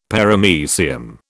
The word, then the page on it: Paramecium: British English